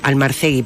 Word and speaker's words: Almarcegui: Lo escribe Patricia Almarcegui, o Almarcegui, perdón, que no lleva tilde.